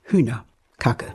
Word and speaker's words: Hühnerkacke: Und die Betonung ist auf? Hühner. Kacke.